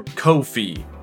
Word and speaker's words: Ko-fi: If you'd like to help support the show, consider joining my Patreon or tipping me on PayPal or Ko-fi, which I've just learned is supposed to rhyme with no fees.